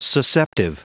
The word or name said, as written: susceptive